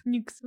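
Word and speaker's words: Nyx: Никс это читается или Нукс? – Никс. – Никс.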